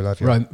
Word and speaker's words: Lavia: Romeo Lavia.